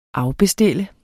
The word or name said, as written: afbestille